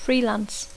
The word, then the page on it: freelance: English